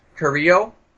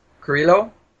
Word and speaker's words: Carrillo: That uh, Sofia Carrillo, I think, unless you want to pronounce the L's, Carrillo, directed with the little stop motion dollhouse and doll parts moving around.